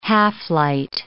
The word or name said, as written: half-light